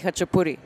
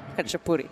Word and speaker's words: Khachapuri: So everybody calls it the bread boat, the eggy bread boat, but it's Ajarski Khachapuri. Khachapuri.